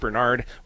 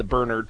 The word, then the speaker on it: Bernard: How do you pronounce that name? Well, I got into the habit of saying Bernard, putting the accent on the first syllable, because if you say Bernard, so many people immediately think of you know, the dog rather than the church history behind it.